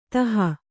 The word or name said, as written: ತಹ